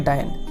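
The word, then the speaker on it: dyin': लेकिन पोएट ने यहाँ पे डी वाई आई एन कर दिया है, ताकि हम इसको प्रोनाउंस करें डायन।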